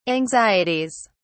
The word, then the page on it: anxieties: British English